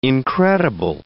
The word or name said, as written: incredible